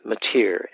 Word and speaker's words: Motyer: If you want to read more on that side of things, I recommend the commentary by Alec Motyer, M-O-T-Y-E-R.